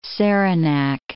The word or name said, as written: Saranac